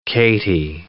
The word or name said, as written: K-T